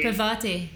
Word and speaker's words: Parvati: It's like Piramati. – Parvati.